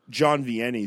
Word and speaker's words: John Vianney: I don't know if he's like a lesser known saint, but I'd say Saint John Vianney has been pretty big for me.